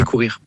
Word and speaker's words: Koor: Alors pourquoi ça s'appelle pas Cor mais Cour? – Cour, c'est pour courir. – Ah. – Alors ça s'écrit K-O-O-R. En anglais, on peut dire Cour.